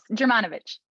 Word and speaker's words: Germanovich: You're close. Germanovich.